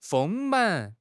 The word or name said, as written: 丰满